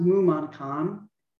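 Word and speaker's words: Mumonkan: So the gateless gate in Japanese, it is pronounced or spelled, it's pronounced mumankan, and that's the same mu, M-U, which means nothingness. Mon, M-O-N, which means gate, and then Kan, K-A-N, which means barrier.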